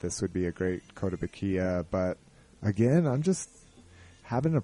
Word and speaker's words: Kotobukiya: And it's mainly because I don't have one of the, I don't have any Kotobukiya. I think that's how you pronounce it. Kotobukiya. – Kotobukiya.